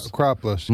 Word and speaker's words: Acropolis: Acropolis.